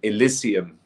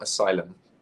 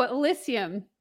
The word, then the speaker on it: Elysium: We co-produced a number of songs on the last album, Elysium, by the way, not Asylum. – Oh, Elysium. – Elysium, yes. – I am saying it wrong.